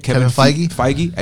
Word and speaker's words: Kevin Feige: Kevin Feige.